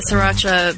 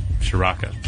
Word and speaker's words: Sriracha: Oh, I said it wrong. I always say it wrong. I always say Shiraka, but I don't. I guess there's not even an H in there.